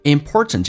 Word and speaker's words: important: Work is a very important， important 这个单词在美式发音，那么 TN 组合 NT 组合经常啊，那个 T 是不发音，所以第一个 T 跟最后一个 T，最后一个 T 不发音叫略音，那么第一个 T 不发音，那么组合在一起，这是生活当中比较慵懒的方式，所以就变成 important， important，气流阻塞一下， important。